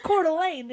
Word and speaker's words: Coeur d'Alene: I was like, Coeur d'Alene, it's a town in Idaho.